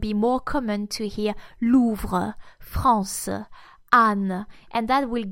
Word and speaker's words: Louvre: This letter will also be mute, simply not pronounced in most regions of France, actually, when it's in final position. It's just not needed, not necessary. You will read Louvre, France, Anne.